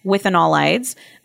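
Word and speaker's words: withanolides: We're using the water extracted ashwagandha, and that's standardized on 8% with anolides. I probably mispronounced that again. – Close enough. – With okay. Anolides. All right.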